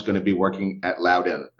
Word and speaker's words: Loudoun: It's pronounced Loudoun. Loudoun. Uh, Loudoun. So if you know me, and it's very easy to remember how to pronounce because I actually am quite loud.